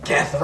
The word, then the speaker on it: Geth: Geth rhymes with death.